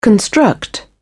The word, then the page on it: construct: British English